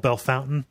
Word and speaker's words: Bellefontaine: where something that is logically called Bellefontaine is called Bellefountain, you know, and a town called Marseille is called Marcelles.